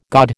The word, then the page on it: gaud: American English